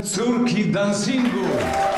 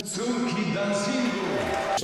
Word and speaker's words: Córki Dancingu: Sorki Dancingu. – Shit. Whatever it was. Whatever. Get out of here. – Pronounce the Polish, please. – Córki Dancingu.